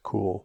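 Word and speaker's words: Kühl: K-U-H-L. – Yeah, it's pronounced cool.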